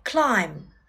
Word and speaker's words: climb: Climb, climb，爬、攀登。 Climb the tree，爬树。 I like climbing，我喜欢攀登。在这里面我们要注意字母 b 不发音。